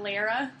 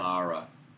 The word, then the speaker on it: Lara: I need to make sure I pronounce Lara properly. – Not Lara? – No. It's not Lara. Lara.